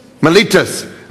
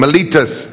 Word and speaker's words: Miletus: how do you say that? Miletus. I've been screwing that name up all week long. Miletus, verse 17.